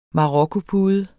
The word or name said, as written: marokkopude